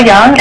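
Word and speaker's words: Yeung: My name is Rochelle Young, and I'm a legislative analyst for the Marijuana Policy Project.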